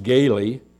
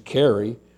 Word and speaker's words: Kari: And this is a young man by the name of, his name is pronounced Gaily, but it's spelled K-A-R-I. And so I was all the time, when I was seeing it on the emails coming across, I thought his name was Carrie, but I got over there and found out that his name is Gaily.